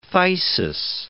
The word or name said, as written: phthisis